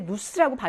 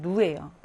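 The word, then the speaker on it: nous: 누라고 하는 거는 원래 누스라고 발음하지 않아요. 원래는 누예요. 그런데 뒤에 나오는 아포농이라고 하는 첫 번째 모음으로 영향을 받아서 누자포농 이렇게 되는 겁니다.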